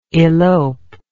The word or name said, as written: eloper